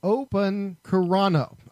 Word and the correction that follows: Okoronkwo: I believe is how you say it. Okoronkwo, that's it.